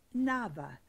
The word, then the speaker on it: naveh: Now, the word I'd like to tell you about today is the Hebrew word nava. It's spelled N-A-V-E-H.